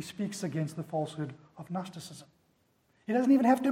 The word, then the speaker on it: Gnosticism: Gnosticism, it has a silent G.